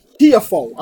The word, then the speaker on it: Tiafoe: Tiafoe.